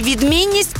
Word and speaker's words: відмінність: Наголос на слові «відмінність» падає на другий склад.